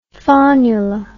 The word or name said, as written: faunula